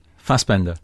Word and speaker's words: Fassbender: Because like, you know, when you talk the way you're talking about Fastbinder, does he pronounce it Fastbender or Fastbinder? Fassbender. Fassbender.